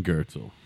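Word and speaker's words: Goertzel: And it's spelled some fucked up way, but it's G O E R T Z E L. – The German Gertzel.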